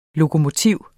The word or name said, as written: lokomotiv